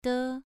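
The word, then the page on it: de: tone 2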